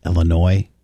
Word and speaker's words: Illinois: Does she not know that it's a French word and the S is silent? It's pronounced Illinois.